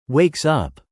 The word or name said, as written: wakes up